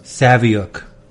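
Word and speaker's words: Saviuk: the Savick rhymes with book.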